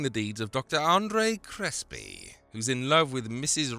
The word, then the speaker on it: Crespi: I know what you're thinking too, did he say Crespi or Crispy? It's called The Crime of Dr. Crespi, but it sounds like The Crime of Dr. Crispy. Everyone in the film pronounces it Crispy, which does make the premise of the film a lot less terrifying.